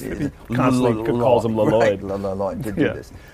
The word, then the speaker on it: Lloyd: It's like a Lloyd.